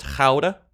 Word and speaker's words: Gouda: You know it's pronounced Gouda? It's Dutch.